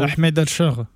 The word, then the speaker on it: Ahmed al-Shara: Ahmed al-Shara. J'espère que je le prononce comme il faut. – Ahmed al-Shara.